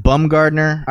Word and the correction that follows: Baumgartner: I always laugh at that name. – Yeah, something like that. He's Austrian or German or something. Felix Baumgartner. – Okay. – I would say Baumgartner.